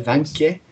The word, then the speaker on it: Wanke: and a Vanke, pronounced uh, spelt W A N K E, uh, a, a a Nazi, a Nazi, Nazi, Nazi, N A Z Z I.